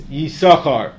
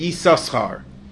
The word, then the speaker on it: Yisachar: For example, in Parashas Vayetze, the Pasuk has one of the 12 Shvatim whose name is Yisachar. That's how we pronounce it. That's Ben Asher. Ben Naftali pronounced it Yisachar.